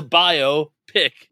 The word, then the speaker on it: biopic: It's a biopic. Wait, biopic. It is. – It's a bio. Pick.